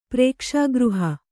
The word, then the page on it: ಪ್ರೇಕ್ಷಾಗೃಹ: kannada